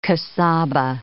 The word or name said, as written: casaba